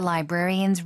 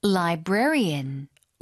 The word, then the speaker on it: librarian: Since we go there almost every week, the librarians recognize us and greet us warmly. – 사서 발음이요. – Librarian. – lie librarian. – 조금 어렵죠? – 그러니까요. 네, 한 번만 더요. – Librarian.